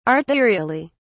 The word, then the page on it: arterially: British English